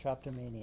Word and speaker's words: Drapetomania: She's written for Mask Magazine and Lies Volume 2, Journal of Materialist Feminism, and has now published a chapbook from Commune Editions, Drapetomania. Drapetomania? I don't know. How are we going to go with that? – People pronounce it different ways, but exactly. I say Drapetomania. – Drapetomania.